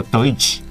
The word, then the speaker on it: Deutsch: 好，它并不是 G，对，其实就是用德语发音，好，这个 Deutsch 这样子一个名称。